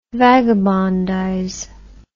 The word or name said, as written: vagabondize